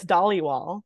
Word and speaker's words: Dhaliwal: Yeah, it's Dolly Wall.